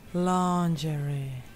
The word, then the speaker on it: lingerie: I'm wearing some really sexy lingerie. – Can I call you back? In British pronunciation, we say lingerie. In American pronunciation, lingerie.